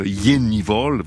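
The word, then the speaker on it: Jenny Hval: Et juste avant, c'était la Norvégienne, alors il paraît qu'on prononce son nom comme ça, quelque chose comme Jennival, voilà, ça s'écrit Jennival, H-V-A-L, dans High Ellis, c'est un extrait de son dernier album, intitulé The Practice of Love.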